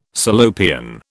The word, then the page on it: Salopian: British English